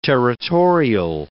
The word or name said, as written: territorial